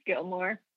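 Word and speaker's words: Gilmour: How do I say your last name, the Scottish accent? – Just Gilmore. – Just Gilmore. The O-U-R doesn't change the way you say it, eh? – The O-U-R doesn't change.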